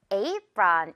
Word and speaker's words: apron: 우리 요리하거나 설거지 할 때 튀지 말라고 그 앞치마를 영어로 apron 이렇게 말해요. 여기 이 A가요. A로 발음이 나는 거죠.